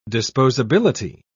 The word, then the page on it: disposability: British English